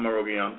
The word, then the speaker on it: Arogyam: How you pronounce it? – I'm Arogyam, like you said.